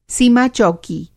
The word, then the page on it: सीमा-चौकी: Hindi